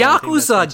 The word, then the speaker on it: Yakuza: How many Yakuza games have you played since I was last It's Yakuza, God damn it. – Say it right, Gaijin.